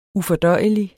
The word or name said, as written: ufordøjelig